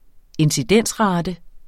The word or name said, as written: incidensrate